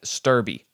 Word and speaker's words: Stöderby: It looks like Sturby. Sturby. Sturby. All day. Stewerby at most, but not Stöderby. What the hell? Adding a whole syllable in there, dude. There's a silent D, and it's also invisible.